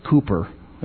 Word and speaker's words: Cowper: Anyway, Cowper, only they pronounce it Cooper, that was his name.